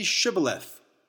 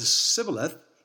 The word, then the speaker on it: Shibboleth: They couldn't make the sh sound, Shibboleth, so they said Sibboleth.